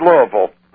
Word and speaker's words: Louisville: You notice I said it right? I said Louisville.